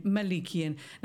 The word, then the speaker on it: Malikian: I can pronounce his surname, Malikian.